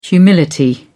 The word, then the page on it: humility: British English